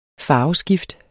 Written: farveskift

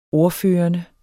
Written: ordførende

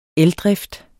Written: eldrift

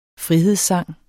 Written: frihedssang

